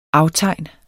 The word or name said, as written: aftegn